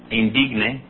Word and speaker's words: indigne: El mismo verso 27, tiene la palabra indigne. – Indigne. To say it with a Spanish pronunciation, be an indigne. – Indigne, si se pronuncia como en español.